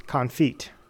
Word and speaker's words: confit: Confit. Confit, I think, is the correct French pronunciation, which is basically like when you take...